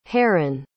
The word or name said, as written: herren